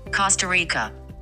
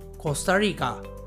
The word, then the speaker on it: コスタリカ: コスタリカ。 – はい。もう一度行きますね。 – コスタリカ。 – はい。えー、この国の名前は発音自体はすごく似ているのですがアクセントの場所が違います英語ですとコスタリカと言っていましたが日本語ではコスタリカと言います。